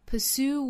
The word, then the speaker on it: pursue: 还要跟前面的 s 去组合 hustle 我们注意这个单词里是含有一个字母 t 的但是字母 t 是不发音的哈我们在发的时候是 s 和 o 连在一起发 hustle If you hustle to pursue 这个词重音在后 pursue what is meaningful to you now meaningful 的 meaning 第一个音节注意是紧音发的时候呢上下齿之间没有缝隙 meaningful What is meaningful to you now?